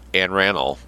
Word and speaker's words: Anne Arundel: But when the huge, fragrant strawberries of Anne Arundel, pronounced Anne Ranel, appeared at last, they went for only 5 cents a box.